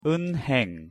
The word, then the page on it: 은행: Korean